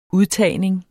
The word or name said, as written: udtagning